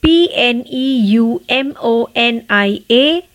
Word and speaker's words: pneumonia: Now let's see the next word which is P N E U M O N I A. Pneumonia. Pneumonia. P यहाँ पर silent है P का उच्चारण नहीं करना है